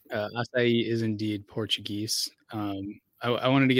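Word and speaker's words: açaí: At the risk of sounding pedantic, just to save you from future embarrassment, that fruit is pronounced acai. – Acai. – Yeah. Just want to make sure we get that right for, I believe that's like a Portuguese word.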